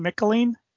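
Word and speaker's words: Michelinie: Jim Shooter, George Perez, Bob Layton, David, how do you say his name? Micheliney. – Rhymes with pickle my knee.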